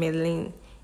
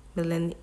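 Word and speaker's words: Melanie: That's the pronunciation. Melin. M-E-L-E-N-I-E. Melin.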